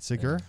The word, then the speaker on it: Segura: He's a sweet, sweet boy who has opened for comedians Dave Attell, Nick DiPaolo, Rich Voss, and Tom Segura. Did I say that right? Segura? Yeah. – Mm-hmm. You're doing it all from memory, so of course you said it right.